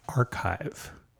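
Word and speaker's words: arXiv: Also, the X in that domain name is for the letter chi or chi, and the Greek letter, and so it's pronounced archive.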